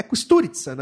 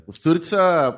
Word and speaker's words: Kusturica: É, é Kusturica, na verdade, né? Mas se escreve Costurica, né? Com K que é um, que é um, um diretor sérvio, se eu não me engano. Sérvio, é. Kusturica pronúncia sérvio.